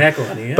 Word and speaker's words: McElhenney: I don't even know how to say his name. – McElhenney, yeah.